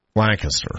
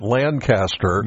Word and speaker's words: Lancaster: And, of course, you've got Harrisburg, York, and the other city down there is Lancaster. – Thank you. Mm-hmm. Unless, of course, you're from out of town and you pronounce it Lancaster. – Yeah. – And you are immediately identified that you're an out-of-towner.